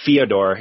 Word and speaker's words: Fyodor: I probably think UFC wise he's probably the GOAT, accomplishment wise, but then there's always, you know, Fedor, or Fyodor if you want to say his name properly,